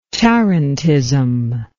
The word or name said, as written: tarentism